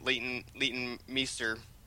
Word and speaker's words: Leighton Meester: I also kind of wish that once they couldn't get Leighton Meester.